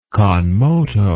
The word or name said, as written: con moto